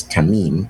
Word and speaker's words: tamim: And that word perfect in the Hebrew is, I mean, I pronounce it right, but it's tamim.